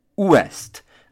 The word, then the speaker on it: ouest: So, now you know exactly how to pronounce and read the word for west. So, it is ouest.